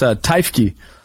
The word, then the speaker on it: Teifke: No, very few people get it right. It's Teifke.